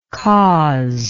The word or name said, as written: causer